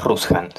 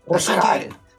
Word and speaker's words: Rujsan: Y, lo pronunciaste bien, ¿eh? porque en general dicen Rushan o Rusian, pero si a mí me gusta por lo menos Rushan, sí, bien marcado. Rushan.